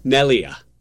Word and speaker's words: Neglia: The G is silent. Jim Neglia with a silent G.